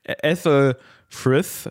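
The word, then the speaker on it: Æthelfrith: Ethelfrith, so würde ich es aussprechen, mit ganz viel Th,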